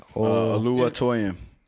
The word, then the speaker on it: Oluwatoyin: Oluwatoyin.